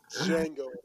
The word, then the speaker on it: Django: No, it's Django. That's it. The D is silent.